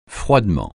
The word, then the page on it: froidement: francais